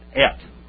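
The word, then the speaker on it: Et (את): That little word to sign a direct object, how do you say that? Et. All right, et, E-T, like you et something, you know.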